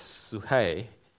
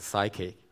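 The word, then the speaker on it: suhei (ψυχή): And Jesus says, "Whoever loves his life," that's suhei, I don't pronounce it as psyche, it's a Greek pronunciation, suhei.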